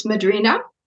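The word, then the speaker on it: Madrinha: How would I pronounce it? Madrina or? – It's Madrina. You don't, in Portuguese, you don't say the H.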